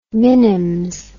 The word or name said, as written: minims